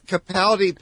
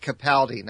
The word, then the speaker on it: Capaldi: Capaldi pronounces it Capaldi, not Capaldi.